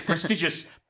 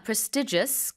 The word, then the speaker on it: prestigious: 발음 두 가지 다 됩니다. Prestigious, 그리고 prestigious 다 되는데요.